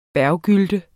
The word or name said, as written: berggylt